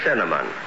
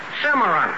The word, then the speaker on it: cinnamon: All right, let's hear you say cinnamon. – Cimarron. – You see, Mary? – Look, mister, it's such a simple word. You just pronounce it like it's spelled. C-I-N-N-A-M-O-N. – C-I-N-N-A-M-O-N? – Yes, Cimarron.